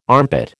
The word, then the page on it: armpit: American English